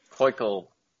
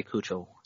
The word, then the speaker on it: Keuchel: Keuchel? It's been so long since I thought about Dallas Keuchel, I forgot how to say his name. – At least he didn't say Kuchel. – Oh.